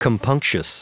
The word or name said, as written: compunctious